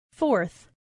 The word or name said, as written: forth